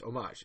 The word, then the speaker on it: homage: It's homage. It's a French word. It's homage. And they're like, no, it's homage. And it turns out that you can actually pronounce it two separate ways.